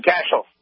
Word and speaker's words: Cashel: You got it right the first time. Cashel, right? – Cashel. Jack Cashel. – It rhymes with Dashel.